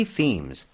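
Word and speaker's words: theme: Okay, we have three themes. – 테마를 발음 어떻게 했죠? theme이라고 한다는 거 기억하셔야 돼요.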